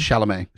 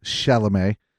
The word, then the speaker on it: Chalamet: Chalamet. Chalamet, uh, or Peter Dinklage.